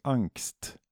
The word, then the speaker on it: Ancst: Och det är eh, ett tyskt band från Berlin som heter, jag tror det uttalas Angst. Angst? A-N-C-S-T.